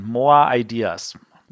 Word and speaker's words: More Ideas: Also, das, das, das spricht man eigentlich sowas wie mehr Ideen. More Ideas.